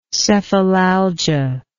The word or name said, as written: cephalalgia